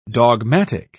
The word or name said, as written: dogmatic